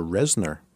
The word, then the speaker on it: Reznor: I'll give an A for not just the Reznor songs. Did I say that right, the Trent? – Yeah, that was going to be my first one, but that's all right.